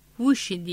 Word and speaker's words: Wuxi: Hangzhou, Suzhou, Wuxi diye okunur.